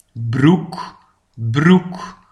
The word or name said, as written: de broek